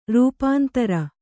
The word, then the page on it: ರೂಪಾಂತರ: rūpāntara